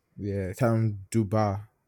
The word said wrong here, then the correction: Dubas: I don't know if I pronounce that properly. It's Dubas.